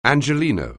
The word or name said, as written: Angeleno